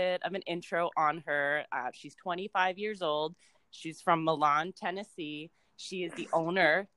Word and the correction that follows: Milan: Did I say that right? – It's Milan. – Milan? – Only people from Milan know that. – It's so funny because I read that and I was like, I need to make sure that I'm going to say this right. I totally didn't.